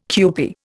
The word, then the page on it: kewpie: Indian